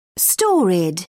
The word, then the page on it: storied: British English